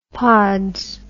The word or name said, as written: pods